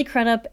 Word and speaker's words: Crudup: Crudup. – Crudup. – I know, like a crusty old guy. – Yeah, I know, exactly. – Not old, but yeah. – Yeah. Billy Crudup and Jennifer Jason Leigh.